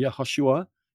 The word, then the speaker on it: Yehoshua: Yehoshua.